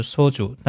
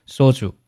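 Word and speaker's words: 소주: 那么这个单词其实第一个字，第一个字正确读法呢是 소 松音，对吧？烧酒，所以它标准发音呢是烧酒，但是呢生活当中一般呢读作紧音烧酒。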